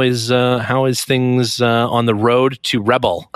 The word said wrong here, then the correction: Rebelle: Rebelle. – I can't say it properly.